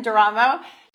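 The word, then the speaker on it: D'eramo: Her name is Dr. Kim Duramo. I think I said that right. Like Geronimo, Dr. Kim Duramo.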